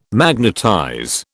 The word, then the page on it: magnetize: British English